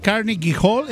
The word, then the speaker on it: Carnegie Hall: Bueno, pues nada más y nada menos que al Carnegie Hall. ¿Es correcto la pronunciación, comadre chula? – Sí, sí, nada más era Wim Wenders, pero el Carnegie Hall está muy bien pronunciado.